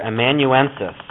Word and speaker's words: amanuensis: The second question, many ancient letters were dictated to a secretary or amanuensis. That's how that's pronounced. Amanuensis, who physically wrote the letter.